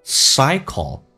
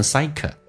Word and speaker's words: cycle: 舌尖抬起来靠向你的上齿龈叫 cycle，不要说成 cycle。